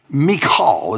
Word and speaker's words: Michal: Saul's daughter, Michal, is actually how you pronounce it.